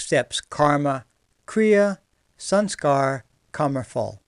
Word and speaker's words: phala: When that reaction does occur, then it's known as something called karma phal. K-A-R-M-A, karma, and phal, P-H-A-L-A. The last A is silent.